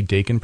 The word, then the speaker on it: Daikin: Quality Daikin products? – Yes. – Did I say that right? – Daikin. Yes, Daikin. Said it right the first time, not the second time.